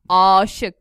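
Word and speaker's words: âşık: No, it's not fronted A. It's aşık. – So a long A.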